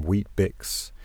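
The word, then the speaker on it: Weet-Bix: Each morning, I'd peel myself off naturally exfoliating, sand-covered dormitory bedsheets, gobble down some wheat bix, being careful not to pronounce the A, as is customary in Australia,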